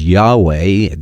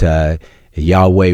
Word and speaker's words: Yahweh: We don't really know how to pronounce that name. It's four letters, YHWH. Yahweh is probably better than Jehovah as far as getting closer to the pronunciation. But Yahweh Ra'ah, the Lord is my shepherd.